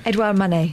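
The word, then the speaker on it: Édouard Manet: Rad Novakovic believes the painting, which he picked up at auction 4 years ago, could be one of Edward Manet's last works. Did I say that properly? Because you're a proper art student, aren't you? – Edouard Manet. Everyone knows what you mean.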